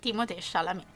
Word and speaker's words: Timothée Chalamet: Timothée Chalamet. – Sì, questa è la pronuncia, ricordiamo che, insomma, ha origini francesi.